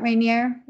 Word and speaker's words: Rainier: That's in Washington, Mount Rainier. I hope I'm saying that right. – Yeah, you're perfect.